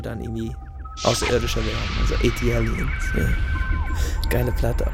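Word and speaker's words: ATLiens: ATLians. ATLians.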